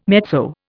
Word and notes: mezzo: Indian

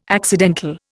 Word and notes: accidental: Indian

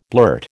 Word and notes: blurt: American English

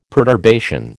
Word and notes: perturbation: American English

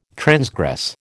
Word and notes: transgress: American English

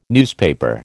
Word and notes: newspaper: American English

